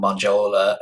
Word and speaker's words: Monjola: I really look forward to hearing more from Manjola. M-O-N-J-O-L-A is how it's pronounced.